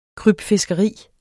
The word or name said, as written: krybfiskeri